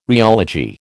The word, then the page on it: rheology: American English